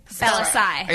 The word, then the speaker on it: Bellassai: So yeah, my name is Matt Bellassai. – I started spelling my last name. I said my first name first. It's Bell Ass AI. That's how you spell it. – Bell Ass AI. – B-E-L-L-A-S-S-A-I.